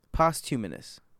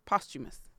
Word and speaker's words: posthumous: But I thought it was humanus. Posthumanus. Posthumous? – Posthumous. – Seems like it's, wow, it seems like there's a whole another syllable in there.